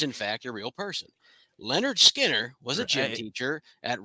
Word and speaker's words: Lynyrd Skynyrd: He was the last living member of the band that played on their debut album, pronounced Lynyrd Skynyrd.